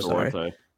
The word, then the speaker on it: Duarte: Duarte.